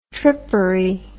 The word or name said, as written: trippery